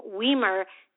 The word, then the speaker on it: Wiemer: My first name is spelled L-I-Z-A, but pronounced L-E-E-Z-A. And my last name, Weimer, W-I-E-M-E-R, is of German origin. Now, from everything I've learned, when it comes down to German names that have two vowels right next to each other, you always pronounce the second vowel.